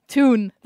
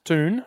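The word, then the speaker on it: Thun: Toon. That's where I was born. – That's T-H-U-N, but pronounced Toon.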